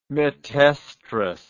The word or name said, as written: metoestrus